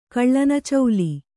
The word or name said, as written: ಕಳ್ಳನಚೌಲಿ